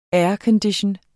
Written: aircondition

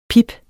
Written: pip

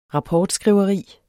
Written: rapportskriveri

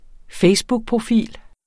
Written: facebookprofil

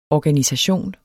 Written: organisation

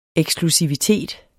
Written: eksklusivitet

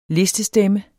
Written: listestemme

